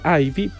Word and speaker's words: IVE: Y de ahí también ahorita vamos a hablar de su nombre, porque Ivy, porque se escribe I-V-E. Podríamos decir que sí se pronuncia Ivy,